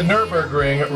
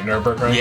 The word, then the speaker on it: Nürburgring: And the Nürburgring record, I said that right, Nürburgring? – Yeah, okay, Nürburgring.